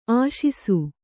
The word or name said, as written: ಆಶಿಸು